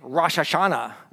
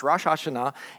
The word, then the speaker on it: Rosh Hashanah: They celebrate what is called Rosh Hashanah. Now, you may say Rosh Hashanah, and that's okay if you want to say it that way, but it's actually pronounced Rosh Hashanah, and that is the head of the year.